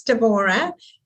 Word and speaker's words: Devorah: Yeah, it's Devorah.